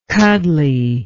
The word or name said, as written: cuddly